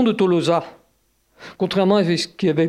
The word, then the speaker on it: Tolosa: les auteurs grecs l'écrivent tolosa », avec un omicron suivi d'un oméga, ce qui vous explique la prononciation Tolosa, la fermeture du second O, et c'est ça qui a donné finalement Toulouse et Toulouse.